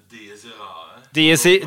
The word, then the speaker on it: Dies Irae: Aldri skjønt hvordan man uttaler det, selvfølgelig. Har du en uttalelse på det I det hele tatt? Hvordan vil du sagt det? Dies Irae. Dies Irae, det låter mye bedre på sørlandsk.